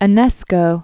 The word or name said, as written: Enesco, Georges